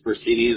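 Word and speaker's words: Furcini: It's Percini. That's F as in Frank. U-R-C-I-N-I.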